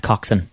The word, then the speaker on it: coxswain: Yeah, so it's spelled coxswain, but pronounced coxswain.